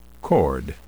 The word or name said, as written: chord